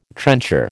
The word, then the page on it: trencher: American English